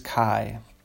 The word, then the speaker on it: Kai: And his character's name is Kai. Rhymes with Tai, like Tai Chi.